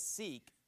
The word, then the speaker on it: Sikh: It's not, people mispronounce it as Sikh. It's actually pronounced Sikh, Sikhism.